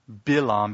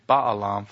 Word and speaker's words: Balaam: Now, I keep pronouncing it Bilam because that's how you pronounce it in Hebrew. We get the English Baalam from some mistransliteration. But anyway, it's not a poor pronunciation. That's the Hebrew pronunciation, Bilam.